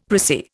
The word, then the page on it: prissy: Indian